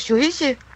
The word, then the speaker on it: Shuichi: Som hedder, ja, jeg ved ikke, om jeg udtaler det rigtigt, Shuichi. – Det lyder rigtigt for mig.